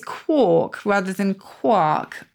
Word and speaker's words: quark: If you look in the Oxford English Dictionary, you can click on audio and get the pronunciation. So here you go. Quark. There you go. And in the US, quirk. There we go.